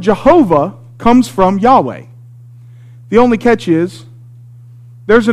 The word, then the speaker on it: Yahweh: And so, in English, we say Yahweh because Yah-wah sounds like Yahweh.